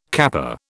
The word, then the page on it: kappa: British English